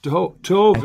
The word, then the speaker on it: Tov: Tove is the right pronunciation. Although you may have heard Matzel Tav, which is sort of contemporary Hebrew Yiddish type pronunciation, but okay.